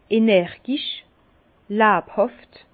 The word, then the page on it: energisch, läbhàft: Bas-Rhin; Herrlisheim